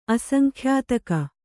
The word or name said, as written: ಅಸಂಖ್ಯಾತಕ